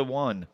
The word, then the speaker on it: won: It's the won. – Oh, the won. Oh, I won. – But it's spelled W-O-N, like won. I won. One?